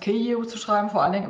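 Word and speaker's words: Kiew (Київ): Also Kiew wäre auf Ukrainisch die richtige Aussprache. Und Kiew ist eben ins Deutsche als äh, Stadtname eingewandert, weil es natürlich über die imperiale Sprache, nämlich das Russische, äh, auf die Landkarten kam. Und deswegen wurde es dann ins Deutsche als Kiew integriert, aber das ist tatsächlich die russische Aussprache, genauso wie der Fluss Dnieper, ne, also auf Russisch, auf Ukrainisch der Dnipro ist.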